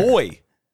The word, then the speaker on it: buoy: They're saying buoy. That's the and to the people from the UK, that's how we pronounce it in the US. Stop, you're getting all over me for the pronunciation. All right, that's how we pronounce it here. It's different than how you pronounce it. – How do they pronounce it there? – Boy. Which I'm like, there's already a word for that. Like, why would you say it that way?